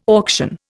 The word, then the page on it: auction: Indian